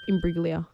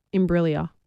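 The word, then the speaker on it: Imbruglia: Imbriglia. – Soft G. – Imbriglia.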